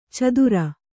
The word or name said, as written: ಚತ್ತರಿ